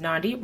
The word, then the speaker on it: Nandu: My name is Nandu, rhymes with Gandhi.